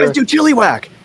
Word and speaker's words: Chilliwack: I remember they even kind of clarified this, you know, from this little town in Canada named Chilliwack.